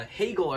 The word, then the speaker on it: Hagel: And uh, Hagel, I believe, tipped it in. It looked like a ridiculous shot from DeBrincat at first, but apparently got tipped in by uh, a guy whose name rhymes with Bagel.